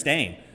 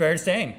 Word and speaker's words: Berenstain: Like, how do you pronounce Berenstain? I've always said Berenstain. – It's Berenstain. – It's Berenstain. – I specifically... – To this day, I refuse to say it, though. – I specifically remember my teacher in first grade actually correcting me on Berenstain and saying Berenstain.